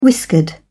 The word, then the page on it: whiskered: British English